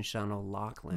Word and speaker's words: Sean O'Loughlin: I took him to lunch, and I said something like Sean O'Loughlin. He's like, actually, it's the Gaelic pronunciation, Sean O'Loughlin.